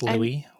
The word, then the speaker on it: Louis: Sorry, I was processing because you're not pronouncing his name right. It's Louis.